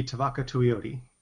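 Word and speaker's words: Tevaka Tuioti: Can you pronounce his name for me? Tuiatu? – That would be Tavaka Tuioti.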